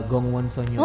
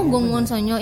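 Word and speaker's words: Gongwon Sonyo (공원소녀): Apa sih cara bacanya? – Bacanya adalah uh, Gongwon Sonyo. – Oh, Gongwon Sonyo. Iya, Gongwon Sonyo. – Karena Gongwon itu artinya taman.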